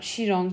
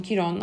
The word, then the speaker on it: Chiron: Chiron, Chiron, hatta Alara'yla da konuştuk bunu. Nasıl telaffuz ediliyor dedim, hepsi olur dedi. Yani ben Chiron diyeyim şimdilik.